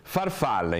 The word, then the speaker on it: farfalla: Farfalla invece, farfalla, una parola piana perché ha l'accento tonico sulla penultima.